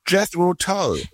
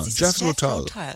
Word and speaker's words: Jethro Tull: Jethro Tull? – Ha. Jethro Tull, ja, Jethro Tull, genau.